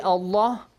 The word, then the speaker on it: الله: Not so, if there is a kasra, okay, you have to pronounce it softly. بسم الله الحمد لله. But if you have fatha or dhamma, you have to pronounce it heavily.